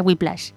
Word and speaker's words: Whiplash: Will Splash. – No, se pronuncia Whiplash. Whiplash.